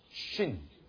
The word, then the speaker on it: shin: 然后左边的是 sin，右边的是 shin，是等于英文的 sh， as shine，啊 sh，所以右边是 sh 的发音，左边呢是 s 的发音。